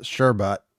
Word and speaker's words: sherbet: Sherbet is the usual word for a frozen sweet dessert made from fruit or fruit juices. Sherbet, with an additional R, is the second syllable pronounced, and sherbet is less commonly used.